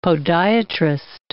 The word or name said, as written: podiatrist